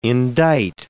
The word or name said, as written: indict